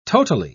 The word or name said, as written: totally